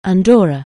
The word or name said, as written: Andorra